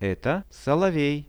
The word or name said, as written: eto solovej